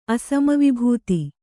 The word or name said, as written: ಅಸಮವಿಭೂತಿ